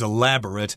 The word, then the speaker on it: elaborate: If it is elaborate, it's got a lot of details and carefully arranged parts and things like that. The verb form of this is pronounced slightly differently elaborate. Elaborate and elaborate.